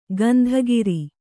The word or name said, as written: ಕುಂದುಕೇರು